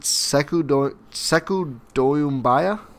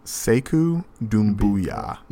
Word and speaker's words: Doumbouya: Is it Sekou Doumbouya? – Sekou Doumbouya.